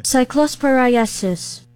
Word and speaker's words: Cyclosporiasis: I had to look, this is how you say it, by the way. – Cyclosporiasis.